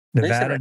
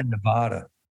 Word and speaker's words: Nevada: Oh, boy, did I say that wrong? Nevada, Nevada. Ooh.